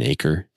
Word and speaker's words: Acker: My name is Ryan Aker. – And how do we spell that last name? – A-C-K-E-R.